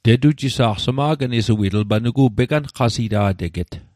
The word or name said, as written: peqanqasita'teget